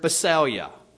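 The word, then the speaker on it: basileia: The Greek word translated kingdom here is the word basalia. Basalia. It's spelled B-A-S-I-L-E-I-A. B-A-S-I-L-E-I-A.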